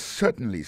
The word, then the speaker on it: certainly: Yes, certainly, sir. Yes, certainly, sir. Certainly. Yes, certainly, sir. L'accent est tombé sur premier syllabe là. Yes, Certainly, sir. Oui, certainement, monsieur. Alors, dans mon CA qui écrit S-I-R, ça veut dire monsieur.